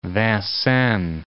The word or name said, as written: Vincennes